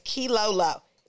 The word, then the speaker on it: Keylolo: My name is Key Lolo. K-E-Y-L-O-L-O.